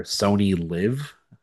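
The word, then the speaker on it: Sony LIV: So yeah, I mean, you shared your login information with me for Sony Live, I guess is how it's pronounced. And like the Live, like the Golf League, which is kind of ironic.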